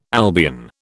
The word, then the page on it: Albion: British English